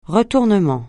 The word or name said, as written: retournement